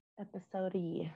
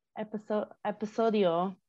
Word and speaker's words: episodio: Episode-y.